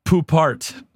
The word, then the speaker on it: Poupart: Alan Poupart.